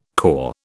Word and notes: cor: British English

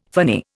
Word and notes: phony: Indian

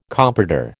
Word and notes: comprador: American English

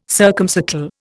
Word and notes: circumcircle: Indian

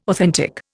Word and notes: authentic: Indian

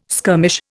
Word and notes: skirmish: Indian

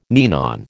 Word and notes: ninon: American English

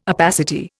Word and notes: opacity: Indian

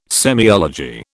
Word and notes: semiology: British English

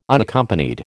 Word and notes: Unaccompanied: American English

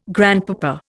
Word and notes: grandpapa: Indian

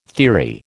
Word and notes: theory: American English

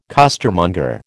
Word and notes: costermonger: American English

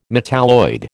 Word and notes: metalloid: American English

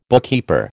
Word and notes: bookkeeper: American English

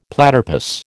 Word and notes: platypus: American English

